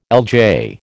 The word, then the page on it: LJ: American English